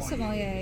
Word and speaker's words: sommelier: But also, talking about drinks, you are a sommelier. Did I pronounce that right? – Yeah, I'm a sommelier, yes.